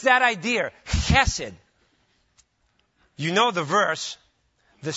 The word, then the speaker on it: chesed: It's the Hebrew word chesed. It's, if you want to know how to pronounce it, it's the same as my first name in Greek. It's that guttural ch sound.